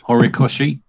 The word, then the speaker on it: Horikoshi: Can't pronounce the last word. – Horikoshi. Horikoshi. Yeah.